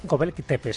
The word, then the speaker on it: Göbekli Tepe: ¿Cómo se dice? Göbekli Tepe. – Göbekli Tepe, sí, tiene ahí su cosilla.